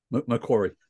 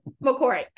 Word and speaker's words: Macquarie: Macquarie. – Macquarie.